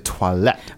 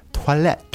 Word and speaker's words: toilette: Right, and then the next one is eau de toilette. – Right, so very important, it's not eau de toilette. – Yeah, and the pronunciation as well is not toilet, it's toilette. – Toilette. – Yes, the French way.